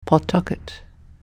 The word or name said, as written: Pawtucket